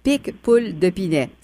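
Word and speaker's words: Picpoul de Pinet: Château des Combes de Bordeaux et Or Marine Pic Poule de Pinet. C'est ça? – Exactement. Vous l'avez bien prononcé.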